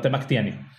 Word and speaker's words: Temachtiani: está organizada por Temactian, Temactiani, creo que así se llama, perdón si lo estoy súper, ajá, súper, súper cagando en cómo se dice, eh, Temactiani o Temactlani, eh, no, Temactiani.